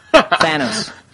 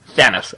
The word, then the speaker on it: Thanos: Thanos. – Thanos, okay.